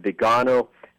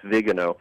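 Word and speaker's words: Viganò: A lot of people call his last name Vigano. The Italian pronunciation, I believe, is Vigano.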